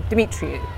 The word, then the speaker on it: Demetriou: Um, did I say that right? You say it properly, Demetriou. – You just, yeah, you nailed it, yeah.